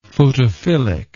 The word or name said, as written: photophilic